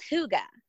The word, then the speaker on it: hygge: So it's called hygge, but it's actually spelled H-Y-G-G-E. And I'm sure I'm sure everyone has seen this. H-Y-G-G-E.